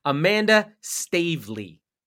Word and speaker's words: Amanda Staveley: Amanda Staveley from the north.